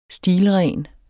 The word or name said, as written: stilren